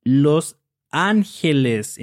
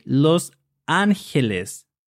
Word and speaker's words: Los Angeles: So it's pronounced Los Angeles in Spanish. because the combination G-E, G-E is pronounced G, not J. So we say Los Angeles, and that means the angels.